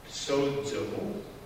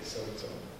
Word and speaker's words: sozo: Did you know that the word for saved in the New Testament, sozo, that's how you say it, sozo, means not just to be saved from danger, but to be healed and made whole and to be made well.